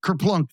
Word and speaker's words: Kerplunk: Is it Kerplunk? – Yes, Kerplunk.